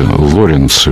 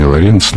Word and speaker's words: Лоренц: Он, простите, Лоренц, его фамилия, конечно, не Лоренц, на первый слог ударения.